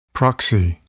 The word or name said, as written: proxy